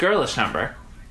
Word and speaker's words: Girlish Number: Well, it said it's pronounced Girlish Number. – They just threw the letter A in the fucking middle of it like that's a thing to do? Could you imagine doing that here? You're like, oh no, the A in the parentheses is silent.